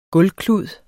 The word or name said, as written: gulvklud